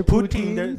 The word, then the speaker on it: poutine: So Vladimir, it's poutine. – They're trying to say it like Bill Cosby.